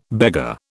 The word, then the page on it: beggar: British English